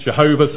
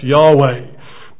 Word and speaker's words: Yahweh: His name, which is translated in our Bibles as Lord, capital L-O-R-D, is a Hebrew word which is just four letters, Y-H-W-H. Some people pronounce it Jehovah, some people say it's Yahweh.